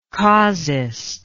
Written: causist

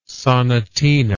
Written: sonatina

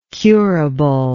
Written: curably

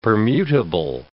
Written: permutable

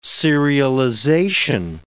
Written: serialization

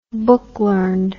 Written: book-learned

